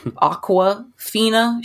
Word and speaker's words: Awkwafina: And Awkwafina, if I'm saying it, no one knows, it's spelled like awkward. Awkwafina.